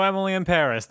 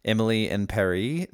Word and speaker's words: Emily in Paris: Emily in Paris is actually supposed to be pronounced Emily in Paris, so it rhymes.